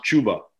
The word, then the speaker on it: Chuba: I was told it's pronounced Chuba.